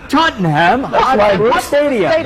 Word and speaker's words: Tottenham Hotspur Stadium: Is that how you say it? – Tottenham Hotspur Stadium.